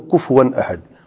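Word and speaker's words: كُفُوًا: اکثر پڑھتے ہوئے کفون پڑھتے ہیں، کف سکون نہیں ہے یہاں پہ، یہاں پہ ضمہ ہے، کفون. وہ بہت بڑی غلطی ہوتی ہے، ولم یکن لہ کفون احد، یہ تلفظ درست کر لے اپنا، ٹھیک ہے نا، ولم یکن لہ کفون احد.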